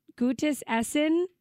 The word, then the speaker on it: Gutes Essen: And this good Gutes Essen. Am I saying that right? Very, very good job there.